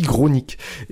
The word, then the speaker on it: Grosnique: Euh, oui, je sais, ça se prononce bizarrement, mais on, dit Gronik.